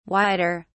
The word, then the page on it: wider: British English